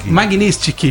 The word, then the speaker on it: Magnitsky: Ele pediu de novo a lei do Magistrink, né? Como é que fala isso? – Quê? – Magnitsky. Ele pediu de novo a lei Magnitsky para o Xandão.